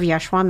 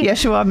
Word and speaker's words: Yeshu’a: Joining me today is author and founder of Yeshu’a Ministries. I said that wrong, but anyway. – You said it right, actually. Oh, yay. Yeshu’a Ministries.